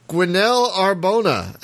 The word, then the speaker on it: Gwennaël Arbona: joining us to talk about Helium Rain is Gwenaëlle Arbona.